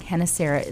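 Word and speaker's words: Gennesaret: And he's now here at the, what would be the Sea of Galilee, but it's pronounced like Hennesaret.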